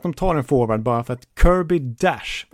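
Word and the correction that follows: Dach: eller Kirby Dock som han ska uttalas, eh, finns tillgänglig i åttonde valet.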